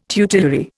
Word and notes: tutelary: Indian

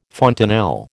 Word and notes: fontanel: American English

